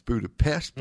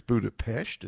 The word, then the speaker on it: Budapest: Flew into Budapest, which is how they say it. You know, in the U.S. it's Budapest, but mm-hmm. over there it's Budapest.